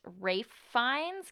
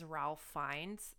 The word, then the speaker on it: Ralph Fiennes: I think his name is pronounced Rafe Fiennes because he's like Scottish or something. So yeah, I didn't realize that. I think everyone's been saying his name is Ralph Fiennes for most of his career, and it's same with his brother.